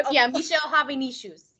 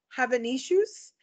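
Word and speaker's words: Hazanavicius: Starring Jean Dujardin, Bernice Bejo, John Goodman, directed by Michelle, I'm going to butcher it, I'm sorry, Hanavicius. Shoes. – I told you it sounded like shoes. – Right. You were right. – Michelle Hanavicius.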